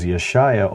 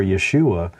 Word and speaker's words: Yeshaya: The reason why I chose Yeshaya over Yeshua is even the sounds, long vowel sounds like oo, did not exist. That is still more modern. So Yeshua is still not accurate. There were a lot of short A sounds like ah and ah. So Yeshaya makes a lot more sense.